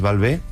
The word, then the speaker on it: Balbé: Y luego el acento en la E viene de mi apellido, que es Balbé, con acento, para así también darle un toque personal.